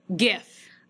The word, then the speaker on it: GIF: And I went in secretly and added a question about do you pronounce it with a hard G or a soft G. And I will tell you that 63.5% of the people pronounce it correctly with the hard G, GIF.